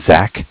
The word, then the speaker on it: Zach: So my name is Zach, spelled Z-A-C-H.